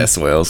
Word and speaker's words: Swailes: Yeah, Swailes.